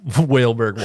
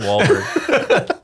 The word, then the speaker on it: Wahlberg: That's like Neil saying Whaleberg Wahlberg.